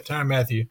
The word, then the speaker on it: Tyrann Mathieu: Granted, they do have their star players, Tyrann Mathieu, right? That's how you say his name? Yeah. Tyrann Mathieu.